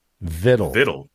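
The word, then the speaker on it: victual: Vittle. That's how it's pronounced. Vittle.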